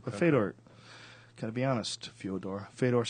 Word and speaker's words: Fyodor: It's actually not even Fedor. Do you know that's what we call it? It's Fyodor. – Yeah. – A Russian friend of mine from Jiu-Jitsu was explaining how you actually say his name. It's Fyodor. Fyodor. Yeah, like Dostoevsky.